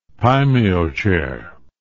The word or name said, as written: paimio chair